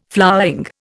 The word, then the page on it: flowering: Indian